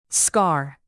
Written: scar

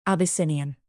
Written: abyssinian

